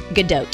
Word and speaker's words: Gadot: Gadot.